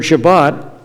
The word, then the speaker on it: Shabbat: The word Shabbat is how you say the Hebrew word in the Sephardic Jewish accent. You'll hear it also pronounced as Shabbos in the Ashkenazi Jewish accent.